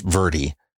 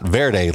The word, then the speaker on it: Verde: And yes, it is pronounced Verde, not Verde like it's spelled, or like the green that it's named for.